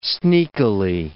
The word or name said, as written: sneakily